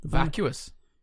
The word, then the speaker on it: vacuous: Is it vacuous? – Is it V? I thought you said F for some reason. – Oh, no, I said V. – Okay. – Oh, it is mind. Vacuous. Yeah. I'm pronouncing it as vacuous.